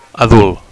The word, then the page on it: adult: Catalan